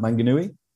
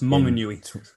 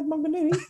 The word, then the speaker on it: Maunganui: Mount Maunganui? – Close, Maunganui. – Maunganui in 2019.